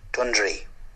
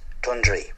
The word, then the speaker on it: Dundry: The locals pronounce it Dundry. Dundry.